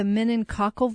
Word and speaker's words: meningococcal: Okay, so they also mention here that Dr. Oz and his guests they missed the fact that the meningococcal vaccine, did I pronounce that right? – Yeah.